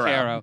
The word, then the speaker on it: Cairo: We have a Cairo. It's Cairo. – But it's pronounced Cairo.